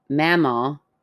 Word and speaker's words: Mamaw: Because Mamaw, mm-hmm. – watched it every day, pronounced Ma like ah. And M, not mama, LOL.